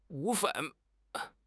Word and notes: 骨干: HSK6

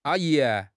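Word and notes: a1 yi2: tone 1; tone 2

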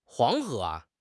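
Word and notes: Huang2 He2: tone 2

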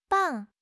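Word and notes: bang4: tone 4

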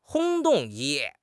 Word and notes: 轰动: HSK6

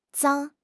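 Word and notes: zang1: tone 1

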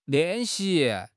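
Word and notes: lian4 xi2: tone 4; tone 2

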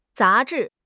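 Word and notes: za2 zhi4: tones 2, 4

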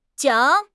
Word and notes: 桨: HSK6

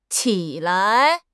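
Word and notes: qi3 lai5: tone 3; tone 5

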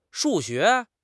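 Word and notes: shu4 xue2: tone 4; tone 2

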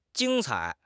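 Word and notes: jing1 cai3: tones 1, 3

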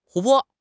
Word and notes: 湖泊: HSK6

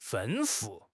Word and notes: 吩咐: HSK6

